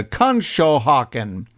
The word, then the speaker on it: Conshohocken: I'm trying to decide how to pronounce this, and you know how I am with pronunciations, but the record company and the band here is in, it's Conshohocken, Pennsylvania. Conshohocken, yeah, that's it.